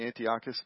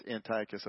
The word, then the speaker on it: Antiochus: So you'll hear me sometimes, I'll say Antiochus, because that's the way I've said it for years, but I'm thinking, I'm learning now that it's Antiochus. I've been mispronouncing it all this time.